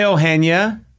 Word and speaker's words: Eugenia: Hi, Eugenia.